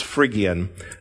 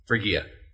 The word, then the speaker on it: Phrygia: The English pronunciation is what you heard there, that it's Phrygia. I can't say it. – Phrygia. – Phrygia.